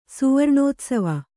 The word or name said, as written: ಸುವರ್ಣೋತ್ಸವ